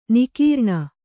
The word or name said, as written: ನಿಃಕರುಣಿ